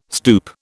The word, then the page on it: stoup: British English